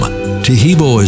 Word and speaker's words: Taheebo: Taheebo is spelled T-A-H-E-E-B, like boy, O, then the word tea, and then the word club.